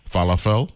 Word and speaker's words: falafel: My moons falafel.